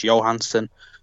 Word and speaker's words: Johansson: People pronounce it like Scarlett Johansson, but it's actually Swedish, so it's the J is pronounced as a Y, so it's Johansson.